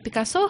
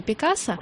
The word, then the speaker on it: Пикассо: По нормам русского языка говорить «Пикассо», хотя он, испанец, конечно, надо на предпоследний слог делать ударение «Пикассо», но дело в том, что в Россию он пришел из Франции. – И чё? – И поэтому мы говорим «Пикассо».